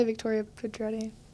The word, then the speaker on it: Victoria Pedretti: I wanted to say Victoria Pedretti, but I don't think that's how you say her last name.